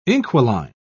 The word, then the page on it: inquiline: British English